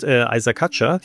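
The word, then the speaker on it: Isack Hadjar: Und Isaac Hatcher, wird er eigentlich ausgesprochen als Franzose. Das habe ich auch letztens gesehen, stimmt, wie er es selber gesagt hat.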